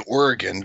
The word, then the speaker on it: Origen: Now, I say Origen because Aziz Atiyah, who was a Copt, pronounced it Origen, and a lot of people pronounce it Origen, but because Aziz Atiyah would know better than anybody I've ever met how to pronounce it, and he pronounced it Origen, that's how I pronounce it.